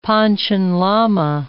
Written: Panchen Lama

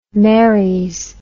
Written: merries

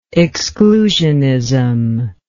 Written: exclusioner

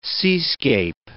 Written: seascape

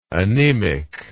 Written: anaemic

